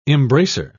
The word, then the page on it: embracer: British English